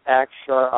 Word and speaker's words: Akshar: A-K-S-H-A-R. And it's pronounced Akshar, Akshar.